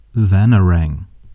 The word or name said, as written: Vanarang